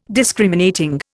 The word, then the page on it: Discriminating: Indian